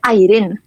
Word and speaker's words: Eirin: Eh, Airin. Como, si, fuera Aileen, pero con R.